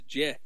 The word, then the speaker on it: Jeh: Jeh, is that really how you say his name? I mean, that's how I've always heard it said, was Jeh.